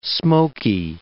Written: smoky

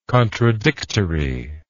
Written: contradictoriness

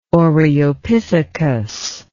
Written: oreopithecus

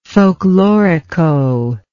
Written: folklorico

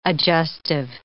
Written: adjustive

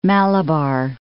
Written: Malabar Coast

